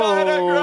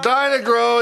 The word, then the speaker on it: Dyna-Grow: Give me a word that rhymes with toe. – Grow. – Dinah Grow. All right. – Dyna-Grow is growing in your fields.